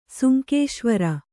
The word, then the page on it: ಸುಂಕೇಶ್ವರ: sunkēśvara